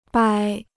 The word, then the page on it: 掰: bāi